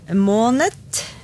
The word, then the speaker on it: måned: Og så kan det også uttales måned med T, og der kommer vi inn på noe som du sikkert kjenner til fra tysk, sant? – Ja, måned.